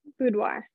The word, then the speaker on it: Boudoir: Boudoir.